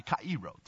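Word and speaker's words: kairo (χαίρω): So this word rejoice is kairo, or kairo, actually, kairo, to say it properly.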